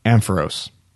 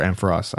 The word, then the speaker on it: Ampharos: And my favorite, Ampharos, or Ampharos. I always pronounce it both ways.